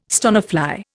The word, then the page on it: stonefly: Indian